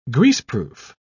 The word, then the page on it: greaseproof: British English